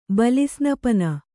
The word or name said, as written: ಬಲಿಸ್ನಪನ